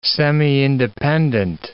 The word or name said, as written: semi-independent